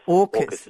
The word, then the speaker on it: AUKUS: AUKUS. I don't know if I've said that right. AUKUS? – AUKUS is the way it's being described, the acronym, yes.